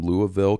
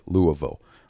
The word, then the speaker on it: Louisville: Aaron from Louisville, Kentucky. You hear that? I said it right. Louisville.